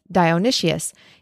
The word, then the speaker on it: Dionysius: And just a note on the name Dionysius when I looked up the pronunciation to double check it, it stated that the pronunciation was Dionysius, even though there's not another I after the first S. I've always heard it as Dionysus, but we'll go with the official pronunciation of Dionysius.